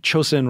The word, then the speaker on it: Chosin: How do you pronounce the reservoir? Chosen? – Chosen Reservoir. – Chosen. Okay, I didn't realize that.